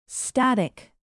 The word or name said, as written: static